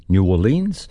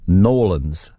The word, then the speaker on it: New Orleans: Some say New Orleans, but when I was there many moons ago, the locals just pronounced it Nolans, Nolans.